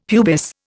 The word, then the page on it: pubis: Indian